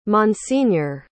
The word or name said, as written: monsignor